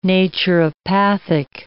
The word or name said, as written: naturopathic